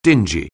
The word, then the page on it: dingy: anglais